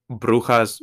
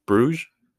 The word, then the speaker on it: Brujas: So we go from France to Brujas, Belgium. That's how you say it in Spanish. – Brujas. – Yeah. Bruges.